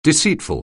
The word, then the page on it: deceitful: anglais